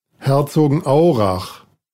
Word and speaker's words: Herzogenaurach: It's not exactly said that like that. Here, let's play this. – Herzogenaurach. – That's how it's said.